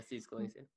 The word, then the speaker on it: Steve Scalise: Wonder if Bernie Sanders will invite Steve Scalise to play? I think that's how you pronounce his name? – Yeah, Steve Scalise.